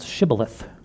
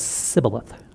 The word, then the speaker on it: shibboleth: They would they set up this this thing with this password kind of thing where they would ask them to pronounce shibboleth. Well, the Ephraimites had no sh sound in their language, so it would come across as sibboleth, and then go, Ephraimite.